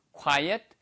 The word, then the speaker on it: quiet: 이거를 quiet는 quiet이에요. quiet. 이거는 조용한이란 뜻이고요. quiet이라고 쓰면은요. 그건 quite. 꽤란 뜻입니다. 그러니까 발음 구분하세요. 얘는 quiet. 그 다음에 꽤라고 할 때는 quite, quite good 이렇게 얘기할 때 쓰는 표현이죠.